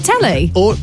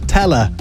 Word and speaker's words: teller: Is it telly? – Teller.